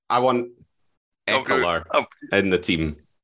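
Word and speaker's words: Ekeler: On stats, Eckler, no extra syllable in the middle, had 709 receiving yards.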